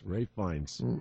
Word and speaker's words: Ralph Fiennes: Ralph Fiennes, if I'm pronouncing that right, was the guy in... – No, it wasn't Ralph. I know he's right, though. Rafe. There it is. Rafe Fiennes.